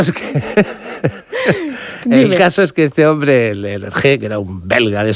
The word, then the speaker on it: Hergé: y utilizó la R de Remy, que allí se pronuncia R de Hergé, y el nombre de George G.